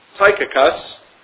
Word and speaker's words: Tychicus: But that ye also may know my affairs and how I do, Tychicus, that's how you pronounce his name, a beloved brother and faithful minister in the Lord, shall make known to you all things.